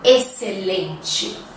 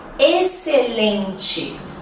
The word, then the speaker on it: excelente: Sim, às vezes ela é silenciosa. Quer ver? As palavras exceto, exceção uhum, e excelente. Vamos repetir? Exceto, exceção, excelente. Sim, se escrevem com X, mas nós não pronunciamos ele, ok?